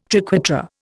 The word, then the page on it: triquetra: Indian